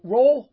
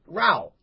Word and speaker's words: Rowell: Rowell? Roll? – It's like towel. – Towel. Okay. Row. Like row. Sam Row.